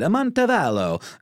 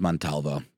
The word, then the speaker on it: Montalvo: I said Amontavallo, and it's Montalvo. But I just I switched up the V and the A.